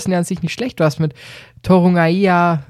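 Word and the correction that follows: Torunarigha: Torunariga, jetzt.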